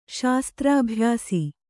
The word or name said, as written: ಶಸ್ತ್ರಾಭ್ಯಾಸ